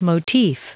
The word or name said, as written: motif